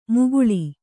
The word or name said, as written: ಮುಕುಳಿ